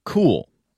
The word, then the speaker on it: Cuil: This morning, they launched a new search engine called Cool. It's spelled C-U-I-L.